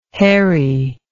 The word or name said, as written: herry